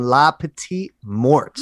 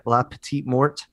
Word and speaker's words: La Petite Mort: Is that La Petite Mort? – Yeah, it depends on how, like, American you want to say it or if you want to go, like, full French.